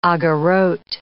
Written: agorot

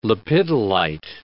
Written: lepidolite

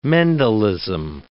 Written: Mendelism

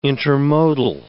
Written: intermodal

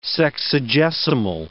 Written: sexagesimal

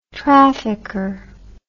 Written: trafficker

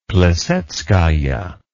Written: plisetskaya

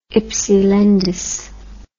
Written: hypsilantis

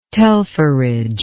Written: telpherage

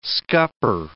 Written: scupper

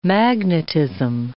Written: magnetism

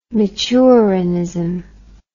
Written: Michurinism

